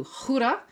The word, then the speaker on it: Gouda: Um, and uh, so to really enjoy it, this the, tonight we have a plan to go to Gouda. Uh, it's spelled, like English Gouda, yes, like Gouda as in the cheese. That's where the cheese comes from uh, originally.